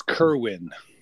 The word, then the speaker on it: Kerwin: It's Kerwin.